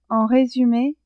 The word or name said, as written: en resume